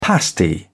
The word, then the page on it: pasty: British English